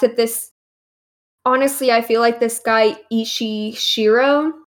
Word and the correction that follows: Shiro Ishii: is that how you pronounce it or how you pronounced it? – Backwards. Shiro Ishii. – Oh, Shiro Ishii.